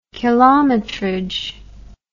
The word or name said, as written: kilometrage